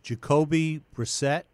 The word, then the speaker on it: Jacoby Brissett: Did you like him better than you liked Jacoby Brissett? Is that pronounced? – Yes. Yeah.